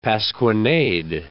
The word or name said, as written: pasquinade